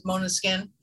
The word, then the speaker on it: Måneskin: It's pronounced, it's spelled M-A-N-E-S-K-I-N, if you guys are not familiar with Måneskin.